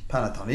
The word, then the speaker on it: panettone: I also didn't know that's how it's pronounced. – Panettone. Yeah, it's... – Okay, now you gave it a nice little bit of that Italian that I think I'm more used to.